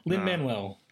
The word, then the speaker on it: Lin-Manuel: It's very easy to... Lin-Manuel, just say that.